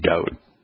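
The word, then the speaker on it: Dode (דוד): But when you find the writing to be hundreds of times as D-W-D, which is just Dode, then there really isn't much of a question as to how the name should be pronounced.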